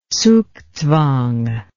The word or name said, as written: zugzwang